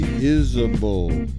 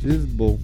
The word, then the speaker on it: Isbell: I have to say, it takes a lot of work for me not to say Isbell. How I want to pronounce his name, I'm not gonna lie, but it's Isbell.